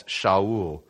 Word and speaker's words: Shaul: So Kish said to Saul, his son, by the way, fascinating, the Hebrew pronunciation for Saul is Shaul. It's fascinating that it doesn't actually come across. We just transliterated it Saul. Technically, his name is Shaul.